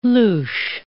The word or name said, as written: louche